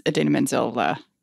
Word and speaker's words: Idina Menzel: The nice Idina Menzel gag. – It's pronounced exactly how it is spelt.